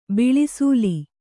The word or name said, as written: ಬಿಳಿಸೂಲಿ